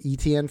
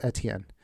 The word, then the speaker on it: Etienne: Which, by the way, the announcer said Etienne. We've been calling him ETN for years, but they get those pronunciations straight from the player. So he's now Travis Etienne.